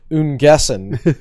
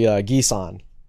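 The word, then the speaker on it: Giesen: Yes, and it's not his name is not pronounced Ungessen. That's how we pronounce names over here. Foreign names we have no idea how to pronounce. Just pronounce it exactly like it looks, right? It's actually Gison. – David, first name? – David Gieson. David Gieson. The N is silent. – Wow. – So he's from the Netherlands, remember, yes. And it's a French pronunciation.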